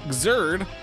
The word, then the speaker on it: Xrd: David and John both say Guilty Gear Xrd, which is correct.